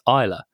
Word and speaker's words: Isla: It's pronounced Isla. And I actually spoke out I as in E-Y-E and then L, L like U-H kind of thing.